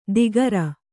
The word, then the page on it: ದಿಗರ: Kannada